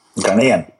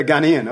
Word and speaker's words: Ghanaian: Is that how you say it? Ghanaian? – Ghanaian. – Yeah, Ghanaian. Okay, so you're born in Ghana, which is in West Africa.